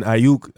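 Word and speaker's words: Aiyuk: I think he's a good quarterback, you know, and then with – Brandon Ayuk, that's how you pronounce his last name, his breakout rookie year.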